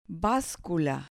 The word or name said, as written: bascula